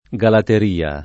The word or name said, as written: Galateria